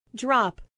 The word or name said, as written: drop